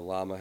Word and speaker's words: Llama: The llama has two L's for some reason.